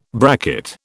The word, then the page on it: bracket: British English